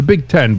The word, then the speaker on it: B1G: It's the Big Ten, but it's written that way. – Okay, because that's their logo now. Is the Big Ten with the one and the G near the I and the G look like the one and the zero or whatever.